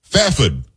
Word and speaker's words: Fairford: Fafid is what I was told how I should pronounce it from K.